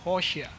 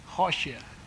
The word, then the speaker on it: Hoshea: In Hebrew, Hosea is pronounced Hoshea. I don't know if I even pronounced that right. Hoshea, okay?